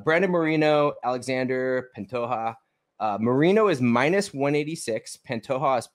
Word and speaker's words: Pantoja: It looks like Pantoja. When I looked it up, just like Duplessis, it said Pantoja. – I think the J is silent. – Yeah, that's how it is in Spanish.